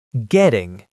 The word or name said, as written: getting